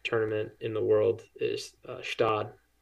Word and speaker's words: Gstaad: Yeah, everybody goes like Gstaad, right? That's like the, but it's just Stad, and it is picturesque.